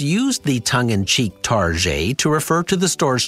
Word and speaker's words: Target: Retailer Target has a long standing nickname, Target. That pseudo French pronunciation